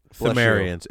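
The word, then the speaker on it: Pthumerians: The ruins that were made by the Puthumarians? – Themarians. It's Themarians. It's just like a silent P. – Good old silent P's. – Like pterodactyl.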